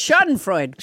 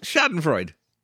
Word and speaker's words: Schadenfreude: wait, how did you just pronounce it? – I don't know. Grishrintit. What was it? – No, it's Schadenfreude. – Schadenfreude.